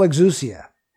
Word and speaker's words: exousia: One is dunamis and one is exousia. Exousia, you'd spell it in English, E-X-O-U-S-I-A.